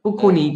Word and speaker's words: Bocconi: Bocconi, genau, in Mailand.